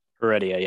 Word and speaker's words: Heredia: Guillermo, how do you say the last name? Heredia? – Heredia, yeah.